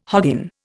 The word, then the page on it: hoggin: Indian